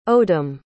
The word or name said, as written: odom